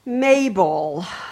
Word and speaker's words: Majel: Mabel. Come. – She says her name is Majel, pronounced Mabel.